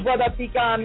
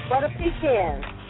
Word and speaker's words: butter pecan: That brother that brother pecan. Like, oh. – No, brother pecan. – Right to how you say it. – Butter pecan. – Wait. – Oh, that's good, right? Butter pecan ice cream.